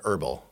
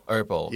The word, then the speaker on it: herbal: Do you pronounce the H in herbal or herbal? – Oh, we say herbal. – Herbal. – Yeah. – Is this supposed? – That's very like herbal. It's very British, isn't it? Yeah. – Is it? – I don't know. Like that hard sound, that herbs, that would be very British pronunciation. In English, you never pronounce that H.